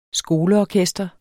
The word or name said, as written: skoleorkester